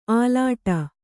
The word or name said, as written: ಆಲೀಢ